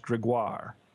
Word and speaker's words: Gregoire: One great case I would like to mention as an example is Governor Christine, and I believe this is pronounced Gregoire. but don't quote me on that. It's G-R-E-G-O-I-R-E. So I think that's Gregoire.